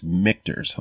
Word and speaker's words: Michter's: He's like, oh, it's Michter's, like Victor's.